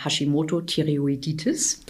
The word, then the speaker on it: Hashimoto-Thyreoiditis: Also, ähm, wer jetzt unter einer Schilddrüsenunterfunktion leidet oder eben auch unter der Hashimoto-Thyreoiditis.